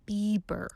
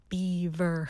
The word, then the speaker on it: Bieber: For instance, the other day, I listened to a song that Justin, I'm going to pronounce this right, Bieber. Not Beaver.